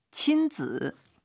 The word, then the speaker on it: 亲子: 亲子 발음 즈 이게 삼성으로 발음해야 됩니다. 친子 해외여행.